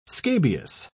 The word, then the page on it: scabious: British English